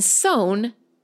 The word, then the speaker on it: Samhain: I didn't know this because I'm not a native English speaker, but the word S-A-M-H-A-I-N is pronounced Samhain in American English and sown in British English.